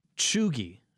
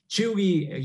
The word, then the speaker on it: cheugy: This first one, Cheugy. And that's spelled Cheugy, Cheugy. What is Cheugy, and am I saying it correctly? – That's correct.